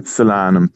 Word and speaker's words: Solanum: Okay, Margaret wants to know is, and I hope I'm pronouncing it right, solanum, is it hardy against frost? – Solanum.